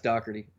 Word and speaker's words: Doherty: And it's actually pronounced Doherty, by the way.